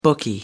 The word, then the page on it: bookie: American English